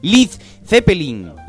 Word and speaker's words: Led Zeppelin: pero a recomendación de Peter Grant, el manager de la banda, se suprimió la de Lead para evitar problemas de pronunciación por parte de los norteamericanos, porque las vocales EA se pronuncian como una I en inglés de Norteamérica. Entonces sería Led Zeppelin.